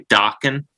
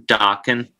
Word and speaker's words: Dokken: I know you're supposed to say, do you say Dokken? Like, Rockin', Dokken. – Yes, rhymes with Rockin'.